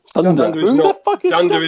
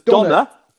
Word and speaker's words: Donner: Dunder is not a reindeer.